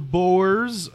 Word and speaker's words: Boer: And he was a boer, I think is how you say it. – Boer, yeah.